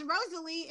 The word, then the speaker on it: Rosalie: Like my real name is Rosalie, but it's spelled fancy. A lot of people don't know that, but they ain't gonna know now. If you don't know me, then you ain't gonna know. But it's pronounced Rosalie.